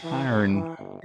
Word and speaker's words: Cairn: Karn.